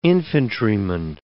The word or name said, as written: infantryman